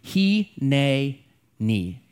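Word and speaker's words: he, ne, ni (הנני): And combined, these words are one word in Hebrew, he, ne, ni.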